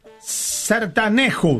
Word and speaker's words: sertanejo: Ese tipo de música se llama sertanejo. Se escribe sertanejo. – Sertanejo. – Se pronuncia sertanejo.